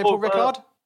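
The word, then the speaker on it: Ricard: You never say the D, Paul Rickard. – Paul Rickard. – I never say Paul Rickard. Do you say Paul Rickard? – I say Paul Rickard.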